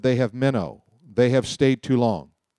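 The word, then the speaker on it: meno (μένω): It's the Greek word meno, M-E-N-O, long O.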